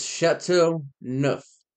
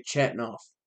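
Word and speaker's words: Chateauneuf: If you speak French, I'm sure you know what it is or how to pronounce it. But it's Chateau Neuf. Chateau Neuf. Or you can pronounce it like we do here in America and just say Chatnoff, which is, it makes me cringe because it just sounds terrible.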